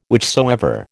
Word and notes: whichsoever: American English

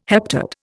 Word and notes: heptad: Indian

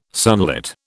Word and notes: sunlit: British English

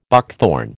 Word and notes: buckthorn: American English

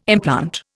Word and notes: implant: Indian

